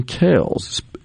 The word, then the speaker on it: Kelce: So the phonetic pronunciation as listed is G-L-A-H-Z, Glaz-go, G-O. There you go. Now, this one I didn't know. Kells, that's the Philadelphia Eagles center, Jason Kells. And it's spelled K-E-L-C-E.